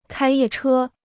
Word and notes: 开夜车: kāi yè chē

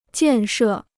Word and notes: 建设: jiàn shè

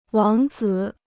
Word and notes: 王子: wáng zǐ

